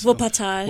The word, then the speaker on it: Wuppertal: Wuppertal. – Wuppertal, okay.